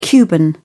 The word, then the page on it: cuban: British English